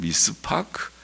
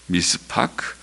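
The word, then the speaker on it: 미스파크 (מִשְׂפָּח): 원문에는 공평과 포악, 공평이 미슈파트, 히브리 말은 미슈파트, 포악이라는 말은 미스파크, 발음이 비슷하죠. 미슈파트, 미스파크, 또 의로움이란 말은 체다카, 그리고 부르짖음은 체아카. 하나님께서는 비슷한 발음의 두 단어를 사용하시면서 대조하십니다.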